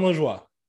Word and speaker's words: Lajoie: You just really pronounced that E that didn't need to be pronounced. – So it's John LaJoie? – John LaJoie.